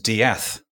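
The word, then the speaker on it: De'Ath: His name was actually spelt D-E, this is his surname, D-E apostrophe A-T-H. And it's pronounced Diath.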